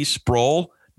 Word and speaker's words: Sproul: Sproul, Jr. Sproul rhymes with soul.